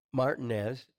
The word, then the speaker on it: Martinez: Our guest today is Pastor Juan Martinez. Am I saying that Martinez. – Right? – Yeah, no, you're saying it right.